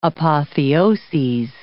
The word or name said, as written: apotheoses